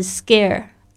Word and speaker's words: scare: OK，第一个我们要记忆的单词就是 scare，特别注意一下这个 c 的发音发“ 葛”的声音，为什么呢？因为在这个浊音，在这个清音后面的浊音，嗯，清音跟清音就是并排在一起的话，这个 sk sk 这个声音呢，它在一起的时候，后面它那个声音会变成浊音，那 k。